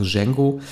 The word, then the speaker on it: Django: Wir sagen beide Django, aber das D, muss ja still sein, also Django.